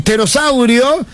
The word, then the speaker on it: pterosaurio: El pterosaurio se escribe, empieza con la P. Claro, con la P. – Pero no se pronuncia.